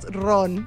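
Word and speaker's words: Rum: Bueno, es ron, se pronuncia.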